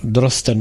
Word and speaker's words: Drosten: A tak pak mohl ten německý doktor Drosten, teď nevím, jestli to byl Drosten nebo Dorsten, Drosten, myslím, že se to vyslovuje.